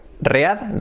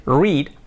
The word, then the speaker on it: read: Read, read. Pero se pronuncia diferente. En presente es read, I read every day. Y en pasado es is regular y se pronuncia read. Like the color, como el color.